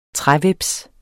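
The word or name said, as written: TRÆHVEPSE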